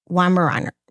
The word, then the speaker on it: Weimaraner: So, quick note, Ocean is a name of the family's 14-month-old Weimaraner.